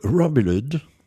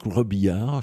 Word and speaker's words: Robillard: Et ensuite, alors là, accrochez-vous au bastingage ou à ce que vous pourrez, le guitariste et chanteur Duke Robillard, que je pourrais prononcer également, comme je l'ai entendu, outre-Atlantique, Duke Robillard, ben oui, pourquoi pas, mais Duke Robillard, je trouve que ça sonne bien, avec Glamour Girl.